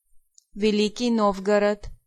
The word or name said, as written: Novgorod